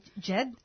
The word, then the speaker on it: Djed: Jed. Uh, Dej, Jed. So the D is silent? Uh, kind of.